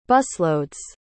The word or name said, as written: busload's